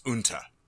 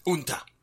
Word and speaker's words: Unter: It's not Uber. It's Unta. – Spell it. – U-N-T-E-R. It's a German company. It's Unta.